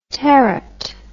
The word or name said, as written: terat